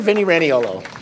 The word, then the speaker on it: Vinny Raniolo: And then our New York representative, Mr. Vinnie Raniolo.